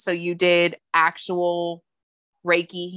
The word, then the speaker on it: Reiki: Reiki. – When I think of Reiki healing, I think of like someone like is a room.